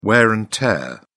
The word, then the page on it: wear and tear: British English